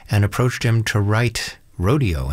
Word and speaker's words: Rodeo: And it's his performance we're going to get with San Francisco Symphony here of Aaron Copland, who pronounced his work Rodeo, not Rodeo or anything like that.